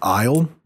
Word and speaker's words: Islay: This apparently is pronounced The Islay is just pronounced Isle. – Yeah, that's a Scottish thing, according to someone.